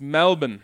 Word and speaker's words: Melbourne: But he says, pronunciation guide, because I know you just said Melbourne. It's Melbourne. – Really? – And I think there's a little bit of Australian accent in there.